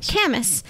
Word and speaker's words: Camas: Camas.